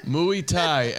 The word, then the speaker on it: Muay Thai: I've trained in my Tai. – Not my time. Did I say that right? – Muay Thai, MMA, where we Not literally fight for the Hey.